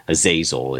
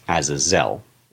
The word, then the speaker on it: Azazel: Uh, and the emphasis is always on the, the last syllable in original Hebrew. So it's not Azazel, it's Azazel, basically.